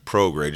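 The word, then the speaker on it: Prograis: How do you pronounce his name? Every time I see it, I can't say it. – Progray. Just call him Regis.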